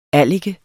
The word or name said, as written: allike